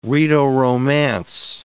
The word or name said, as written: Rhaeto-Romance